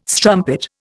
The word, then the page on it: strumpet: Indian